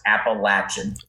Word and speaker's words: Appalachian: It is pronounced Appalachian. It's not Appalachian. It's not Appalachia. It's Appalachian Mountains, Appalachian State University. In fact, App State sells t-shirts with the pronunciation guide.